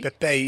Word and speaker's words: Pepe: Is that how you say it, Pepe? – Pepe, yeah, he plays for Real Madrid.